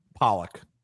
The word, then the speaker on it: Pollock: Pollock.